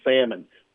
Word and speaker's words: salmon: My mother was big on you don't pronounce the L in salmon.